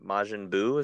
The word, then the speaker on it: Majin Buu: I've seen the Dragon Ball Z saga, most of it, with, like, Cell, Frieza, Nappa, and I think Majin Buu, is that how you pronounce it? – Oh, yeah.